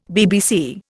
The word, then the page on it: BBC: Indian